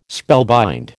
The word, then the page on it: spellbind: American English